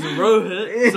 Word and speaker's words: Rohit: It's Rohit, so it's R-O-H-I-T with another T. So two T's. And then .Verma. Fuck. V-E-R-M-A with another A.